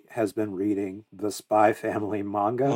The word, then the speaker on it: Spy x Family: So, we have this manga that came out a while ago from Tatsuya Endo called Spy X Family, which is really just pronounced Spy Family.